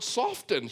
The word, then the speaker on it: soften: And I said, well, how about the word soften? She said, that too. It drives me nuts. The T is silent.